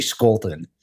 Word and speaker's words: Scholten: We we pronounce it the German way when it's actually Dutch, and so it should be Skolten.